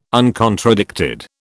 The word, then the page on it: uncontradicted: British English